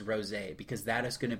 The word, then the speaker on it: rosé: And I'm sorry for pronouncing it rose because it was rosé.